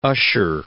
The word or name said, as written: Ussher